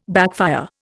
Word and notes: backfire: Indian

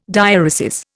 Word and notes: diaeresis: Indian

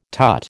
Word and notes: tut: American English

